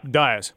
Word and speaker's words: Dias: Is it Dias or Diaz? – Dias.